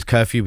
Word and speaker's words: Kerfew: And my artist name is Curfew, which is spelled K-E-R-F-E-W.